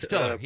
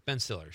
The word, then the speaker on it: Stiller: Ben Stoller was was so nice. Did I get the name right? – Stiller, yeah. Ben Stiller, sure.